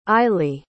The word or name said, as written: aili